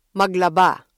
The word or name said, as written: maglaba